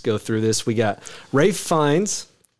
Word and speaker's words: Ralph Fiennes: It's Ralph Fiennes.